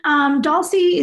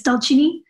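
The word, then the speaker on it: dalchini: So it's Dulcini. And I couldn't pronounce it. – Sure. – I used to just say Dulcy and no one corrected me in my household.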